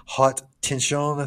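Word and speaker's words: Haute Tension: It's like a Hot Tension, I think is the way you say it.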